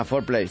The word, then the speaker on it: foreplay: Fair play. – No, es fore, se escribe. – Ah, foreplay. – Sí, o sea, yo pensé que era fair. Me sonaba así también, pero no.